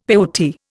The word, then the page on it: peyote: Indian